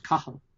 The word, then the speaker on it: Cathal: And sure enough, the original version of his name was Cahal, C-A-T-H-A-L, but pronounced Cahal.